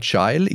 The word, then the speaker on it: Guile: Ich muss das sagen, du sagst jetzt immer mal Gile, ich sag geil. Also ich fand damals auch der, die Aussprache des Namens, die war einfach aus der Hölle. Du hast doch damals als Zehnjähriger nicht gewusst, wie du diesen Namen aussprechen willst. G-U-I-L-E.